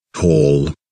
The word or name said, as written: Hall